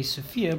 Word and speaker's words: Sofia: I should rather say Sofia, because this is how it's pronounced in English.